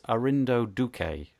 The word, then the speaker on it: Arindo Duque: Today, all the way from Brazil, we have, now, I hope I'm not going to massacre this, it's Arindo Duque.